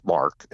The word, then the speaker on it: Mk: So first of all, just to be clear, the MK is pronounced Mark. – It's short for Mark.